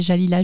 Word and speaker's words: Jalila: Oui, moi, mon nom est Jalila Jbilou.